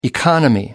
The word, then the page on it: economy: American English